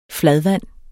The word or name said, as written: fladvand